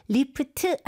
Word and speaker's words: lift: 그리고 이름절로 발음하셔야 돼요. Lift 아니고 lift. – Lift. 앞에 강세를 주시고요.